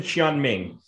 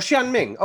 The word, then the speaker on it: Xiaoming: Yes, Lin Xiaoming. – Oh, Xiaoming. Okay, yeah. Like the city, Xian.